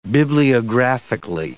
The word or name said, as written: bibliographically